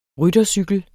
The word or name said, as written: ryttercykel